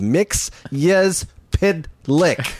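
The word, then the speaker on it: Mxyzptlk: And he went syllable by syllable of Mix Yes Pid Lick.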